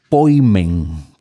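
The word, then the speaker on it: poimen (ποιμήν): Y es apasionante porque cuando el Nuevo Testamento se refiere a pastor, a ese poimen, que es como en griego se pronunciaría, nosotros vemos que ese elemento se le adjudica muy especialmente a la figura de Jesús.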